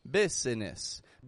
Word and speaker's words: business: Now, much like Wednesday, business is not spelled the way that it's pronounced. The S changes to a Z and the I that's in the middle disappears altogether. I'm not saying business, business, but business.